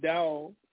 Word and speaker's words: Tao: One of the images that popped in my mind right away is the Tao, and that Tao is spelled T-A-O. It's pronounced Tao, most when you hear Tao, and it has that yin and yang symbol.